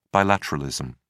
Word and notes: bilateralism: British English

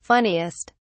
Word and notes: funniest: British English

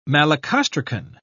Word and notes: malacostracan: British English

